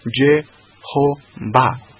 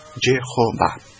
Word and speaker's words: Jehová: Se transforma en E, de tal forma que al meter la E, la O y la A sobre esas cuatro consonantes, quedó el texto leído pronunciado como Jehová, Jehová.